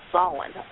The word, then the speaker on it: Samhain: And when you see that word Samhain, it does not look, when you look at it, it's spelled S-A-M-H-I-N, but it's pronounced Samhain, or in some cases, some people call it Svana.